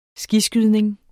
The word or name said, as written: skiskydning